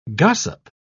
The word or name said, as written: gossip